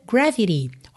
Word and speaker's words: gravity: Então aqui esse T de gravity virou rarara, gravity.